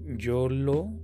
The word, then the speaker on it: JoLo: Eh, antes de eso, la página de Instagram quedó siempre con el nombre Yolo eh, pero se escribe J-O-L-O-W-X, todo junto.